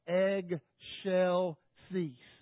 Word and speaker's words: Excelsis: We should say like egg shell cease. Got that? So instead of Excelsis, say egg shell cease. That's the way it should be pronounced.